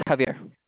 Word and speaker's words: Javier: My name is Javier. – Spell that for me, please. – Okay, it's a J-A. V-I-E-R.